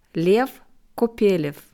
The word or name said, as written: Lew Kopelew